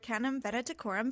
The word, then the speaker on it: Canum Venaticorum: And it is an alpha 2 or an alpha squared Canum Venaticorum variable star.